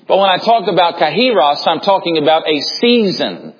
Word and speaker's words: kairos: And then the second word is, I might pronounce it a few different ways this morning, and you'll have to forgive me if I say kairos, or the proper pronunciation is kairos.